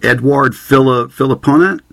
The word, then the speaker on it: Edouard Philipponet: is it Edouard Philipponet? – Yeah, well, you said it actually quite well, but if you were to give it a little French flair, you would say Edouard Philipponet.